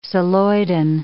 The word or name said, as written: celloidin